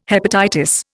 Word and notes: hepatitis: Indian

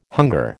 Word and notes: hunger: American English

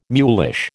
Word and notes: mulish: American English

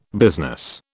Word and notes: business: British English

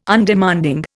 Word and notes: Undemanding: Indian

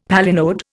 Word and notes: palinode: Indian

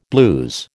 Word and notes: blues: American English

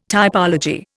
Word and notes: typology: Indian

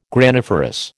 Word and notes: graniferous: American English

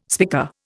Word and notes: spica: Indian

